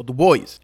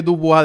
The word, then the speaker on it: Du Bois: Hay un debate sobre cómo se pronuncia. Yo siempre digo Dubois porque al parecer esa era la forma de pronunciar el apellido originalmente, pero Du Bois, debido a que hablaba inglés primariamente, decía Du Bois.